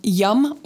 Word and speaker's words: yam: The Bija mantra is, for the heart chakra, is yum, Y-A-M, but pronounced yum.